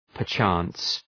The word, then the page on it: perchance: pər’tʃæns